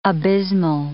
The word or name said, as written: abysmal